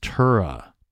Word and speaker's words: Turah: Unfortunately, Captain, Siobhan's body was found in a culvert by a county road worker at the Tura, I believe it's pronounced Tura, T-U-R-A-H exit from Interstate 90.